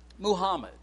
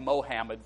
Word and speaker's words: Muhammad: As we think about Muhammad, and I'm using the pronunciation and the spelling that the Muslims use. I know we've called him Mohammed for a long time.